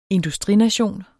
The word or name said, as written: industrination